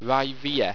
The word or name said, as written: wriveha